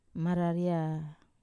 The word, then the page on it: marareia: Wamesa